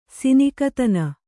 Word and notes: ಸಿನಿಕತನ: kannada

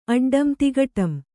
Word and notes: ಅಡ್ಡಂ: addam

